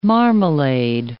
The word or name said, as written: marmalade